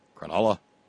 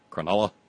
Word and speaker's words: Cronulla: Cronulla, as like in null and void. Cronulla.